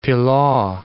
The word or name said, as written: pilaw